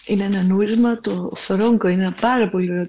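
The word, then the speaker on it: Zorongo: Γράφεται Ζορόγκο, αλλά προφέρεται Θορόγκο.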